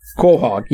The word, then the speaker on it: quahog: I believe it's pronounced quahog, but I am not sure. – Give me a second. I believe you are correct. Quahog.